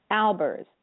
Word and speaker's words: Albers: Also, you can reach me at Facebook, Bonnie Mosenter Albers, so I'll spell it for you, B-O-N-N-I-E, and then my maiden name was Mosenter, M-O-Z-E-N-T-E-R, Albers, A-L-B-E-R-S.